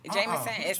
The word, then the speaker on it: JMSN: Jameson. It's spelled J-M-S-N.